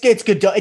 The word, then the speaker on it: Gadot: Ooh, the Gal Gadot run, or Gadot, whatever her name is. Gadot. – it's Gadot. It doesn't matter, but it's Gadot. As her stylist says Gadot in interviews, and it drives me insane.